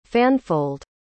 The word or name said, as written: fanfold